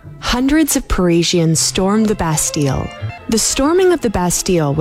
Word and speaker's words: Bastille: Okay, today is Bastille Day in France, national holiday, or to be correct pronunciation, Bastille.